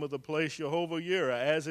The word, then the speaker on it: Jireh: And Jehovah Jireh, we say. Jehovah Yireh is the correct pronunciation in Hebrew.